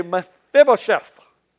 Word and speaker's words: Mephibosheth: His name is Mephibosheth. Now, if you really want to say this word, you got to lick your lips and say Mephibosheth.